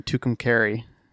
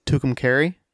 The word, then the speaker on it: Tucumcari: And it's in Tucumcari. – It's in what? – Tucumcari, New Mexico.